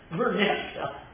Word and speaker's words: Merneptah: but it is equally as often spelled this much easier to pronounce way, Merneptah.